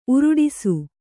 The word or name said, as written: ಉರುಡಿಸು